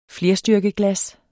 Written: flerstyrkeglas

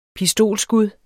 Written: pistolskud

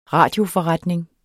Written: radioforretning

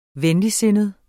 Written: venligsindet

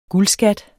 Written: guldskat

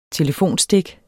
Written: telefonstik

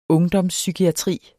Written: ungdomspsykiatri

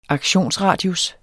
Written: aktionsradius

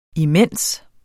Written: imens

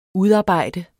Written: udarbejde

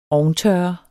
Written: ovntørre

